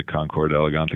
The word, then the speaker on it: Concours d'Elegance: You did, and it's a pleasure to be on here and talk about the Concours d'Elegance at Kopsholm.